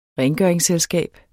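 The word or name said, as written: RENGØRINGSSELSKAB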